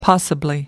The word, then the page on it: possibly: American English